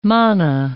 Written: mana

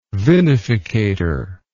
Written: vinificator

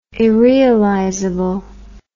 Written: irrealizable

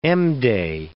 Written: M-day

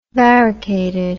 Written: varicated

